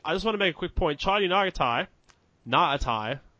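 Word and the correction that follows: Ngatai: Stop adding a syllable. – Nagatai. – He ignore the G.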